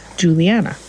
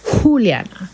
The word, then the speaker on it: Juliana: J-U-L-I-A-N-A. And I can't tell you how many times I've had people say, oh no, it must be an H, H-U-L.